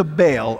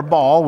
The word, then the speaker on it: Baal: Jezebel means husband to Baal, or Baal, whichever way you want to pronounce that.